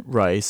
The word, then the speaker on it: Rice: For those who don't know me, my name's John Rice, spelled like the food.